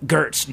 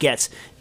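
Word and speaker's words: Goetze's: It's written like Gertz, G-O-E, like T-Z. It's pronounced Getz.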